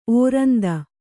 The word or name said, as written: ಓರಂತು